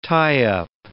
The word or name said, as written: tie-up